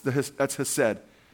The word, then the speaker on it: hesed: And that's hesed. which you translate that in English from the Hebrew, H-E-S-E-D, hesed. I'm not going to say it like the Jews and honk a loogie, hesed, you know.